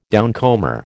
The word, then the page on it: downcomer: American English